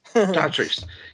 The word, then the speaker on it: Dotrice: who's played by Dotrice, I think is that how you pronounce his name. – Yeah, Dotrice. Yeah, 133 credits.